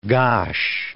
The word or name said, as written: gosh